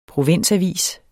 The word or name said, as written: PROVINSAVIS